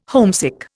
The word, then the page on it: homesick: Indian